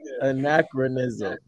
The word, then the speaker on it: anachronism: I said aconochrism. God damn it. I'm mixing two words. My bad, because I was going to pick another word. Anachronism. God damn. Anachronism. Okay. Anachronism. So basically, it's something that doesn't fit its time period.